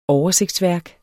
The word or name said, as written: oversigtsværk